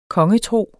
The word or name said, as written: kongetro